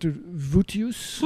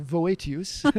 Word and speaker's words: Voetius: And so while here, my primary reading has been the work of a man whose name you pronounce as Gisbert Vutius. – Vutius, yes. – Vutius, and whom I pronounce Voetius.